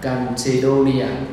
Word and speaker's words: Chalcedonia: Chalcedonia, trong đây gọi là đọc là Chalcedonia, và trong tiếng Việt chúng ta đọc tắt gọi là cộng đồng Cande.